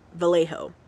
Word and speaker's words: Vallejo: Darlene Farron and Michael Magoo, I definitely butchered that, they were parked at Blue Rock Springs Park in Vallejo. Vallejo. I think it's Vallejo. – I think you said it right the first time.